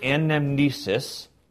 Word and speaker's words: anamnesis: It's the word, Greek word anamnesis.